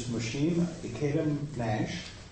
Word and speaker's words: Mushim Ikeda-Nash: Our speaker this morning is Mushim Ikeda-Nash.